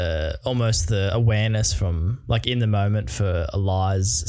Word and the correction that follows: Alizé: sorry, I didn't know how you pronounce Alizé. – Her name. Alizé to actually say that in the moment.